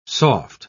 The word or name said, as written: soft